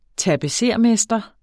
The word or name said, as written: tapetsermester